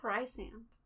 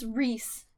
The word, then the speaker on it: Rhysand: Like Rysand. That's how I say his name. – Right? And it's Reese. – Who spells Reese like that? – R-H-Y-S-A-N-D. – That's just wrong. – How does the R-H-Y make a Reese sound? – I don't understand.